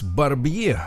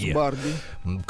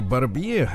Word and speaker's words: Барбье: Э, фамилия у него пишется так же, как Барби. Угу понимаете, да? То есть э, у вас сначала вот То Барбье. Клаус Барби. – Клаус Барби, но на самом деле Барбье это жуткий человек.